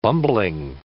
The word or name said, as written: bumbling